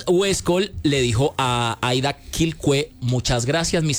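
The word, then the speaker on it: Quilcué: Quilcue. Sí, Quilcue, señor, por fin lo dije bien.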